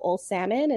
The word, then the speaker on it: O'Salmon: So it's Nicole, the letter O, Nicole O'Salmon, and salmon is spelt just like the fish.